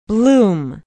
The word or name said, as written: bloom